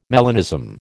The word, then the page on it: melanism: American English